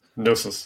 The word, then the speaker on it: Gnosis: Is it Gnosis? I ask this all the time. Gnosis? Gnosis? – Gnosis. – Gnosis. – Gnosis. Gnosis. I just can't pronounce it. Gnosis. – Silent G.